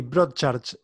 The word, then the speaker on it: Broadchurch: Eh, recomiendo también eh, de Amazon, está solamente la primera temporada, la serie tiene tres, pero Broadchurch, ¿está bien pronunciado? – Sí, Broadchurch, eh, con Olivia Colman.